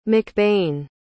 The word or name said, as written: mcbain